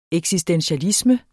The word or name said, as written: eksistentialisme